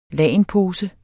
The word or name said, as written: lagenpose